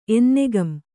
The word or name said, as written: ಎನ್ನೆಗಂ